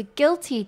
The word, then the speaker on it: guilty: 最后我们要来看的是 guilty，指的是罪恶的，它的 g-u-i-l 这边你可以把它想象成 u 不发音，只有发 gil 的音 g-i-l 就是发 gil。